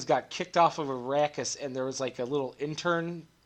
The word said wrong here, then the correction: interim: Interim family.